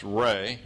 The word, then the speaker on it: Re: You'll see sometimes his name spelled R-E, pronounced Ray, or R-A, pronounced Ra.